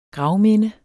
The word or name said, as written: gravminde